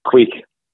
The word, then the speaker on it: quaich: How about a quake or a quaitch? How do you pronounce that? – Yeah, quake. Yeah, quake. So it's spelled Q-U-A-I-C-H is a quake.